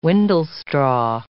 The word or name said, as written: windlestraw